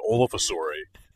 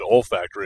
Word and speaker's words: olfactory: My coworkers listened to me do this goddamn show saying olfasori, and then somebody just randomly around me said olfactory, and I was like, what? Is that how you pronounce that?